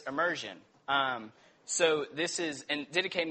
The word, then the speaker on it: Didache: And it's did-ache is how you spell it. So D-I-D-A-C-H-E.